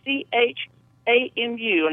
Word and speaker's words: Nachamu: It says it has a special name for this week's Shabbat, and it's in Shabbat, and then NACHAMU, and I was wondering if you could tell me how to pronounce that and what it means. – Yeah, just the way you spelled it.